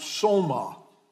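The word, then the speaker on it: soma (σῶμα): It's a word in the Greek that would be pronounced soma.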